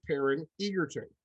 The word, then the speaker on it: Taron Egerton: And that's another other, other than Taron Egerton.